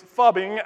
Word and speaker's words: phubbing: It's phubbing, as in fish and fate,